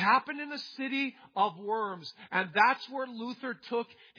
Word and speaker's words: Worms: In Germany, the city that they met in was actually pronounced Worms. It's a V sound in German.